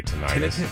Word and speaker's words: tinnitus: Some people say tinnitus. – I say tinnitus. – Tinnitus? – Yeah. – Tinnitus. I guess is the proper way to say it.